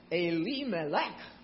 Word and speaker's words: Elimelech: Dad's name, and this is all laid out in the first couple verses, Dad's name is Elimelech. That's how we pronounce it. But in Hebrew, it's Elimelech.